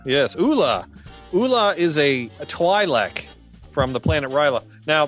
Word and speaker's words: Twi'lek: I would pronounce that Twi'lek.